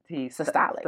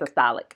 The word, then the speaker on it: systolic: Systolic. – Systolic.